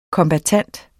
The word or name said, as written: kombattant